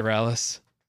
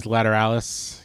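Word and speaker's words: Lateralus: I think the last Tool album that I actually like, I think I've only bought one Tool album, and it was Lateratus, whatever, how do you say it? – Lateralis. – Yes, Lateralis. Yeah, as I like Schism so much.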